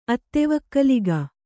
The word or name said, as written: ಅತ್ತೆವಕ್ಕಲಿಗ